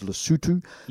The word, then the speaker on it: Lesotho: Lesotho is a wonderful place, commonly mispronounced as Lesotho. – Yeah, right, because it's spelled, what, L-E-S-O-T-H-U. – T-H-O. – T-H-O, sorry. – Yeah, so right. if you look at it. – Yeah. – But the pronunciation is Lesotho.